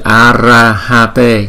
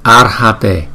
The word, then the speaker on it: Arhate: Rồi chữ này là Arhate. Giống như là Arahate nhưng mà cái chữ R này không có chữ A, không có chữ A cho nên chúng ta đọc là Ar, Ar, Arhate, Arhate, Arhate, Arhate.